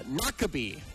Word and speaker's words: Noquebay: Is it Shawano Lake, Lake Wissota, or Lake Noke Bay? – Nakabe. – That's not how it's spelled. – Noquebay, Nakabe.